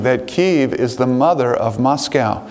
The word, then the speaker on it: Kyiv: Kyiv. All right. Not Kiev. That's the Russian way to pronounce it. We don't pronounce it that way anymore. Okay. We say Kyiv.